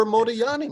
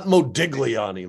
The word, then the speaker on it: Modigliani: I prefer Modigliani myself, not Modigliani, like they say out here in America. Right. Like, not how you pronounce it. Yeah, it's a Yi, G-L-I, Yi.